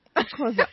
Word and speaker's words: Xhosa: Xhosa. – I'm ready. I can't just pronounce that. Xhosa.